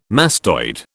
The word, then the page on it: mastoid: British English